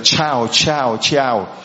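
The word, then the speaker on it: ciao: Người ta bảo không, đó là phát âm của tiếng Ý là chữ C, chữ Y, chữ A, chữ O, phát âm giống như là chào chào chào, là tiếng chào của tiếng Ý chứ không phải là tiếng chào là tiếng Việt Nam mình.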